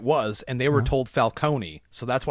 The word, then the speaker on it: Falcone: Is it Falcone or Falcone? – Falcone. – Because in the Nolan stuff, they call him Falcone. – Yeah, it's Falcone.